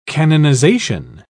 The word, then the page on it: canonisation: British English